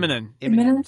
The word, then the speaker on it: Immonen: Immonen.